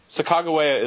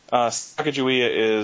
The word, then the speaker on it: Sacagawea: Well, Sacagawea is our pronunciation, but Sacagawea is probably the most common pronunciation. Although there are many different pronunciations. But so Barb, her, you know, her take on it is Sacagawea is phonetic pronunciation as the as Lewis and Clark wrote in their journals.